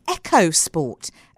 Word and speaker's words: EcoSport: You can't swing a dead cat in South Africa without hitting an EcoSport or an EcoSport, as I discovered it's pronounced this week.